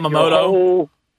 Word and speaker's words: Yamamoto: And then you got Yamamoto. – Yamamoto, there you go.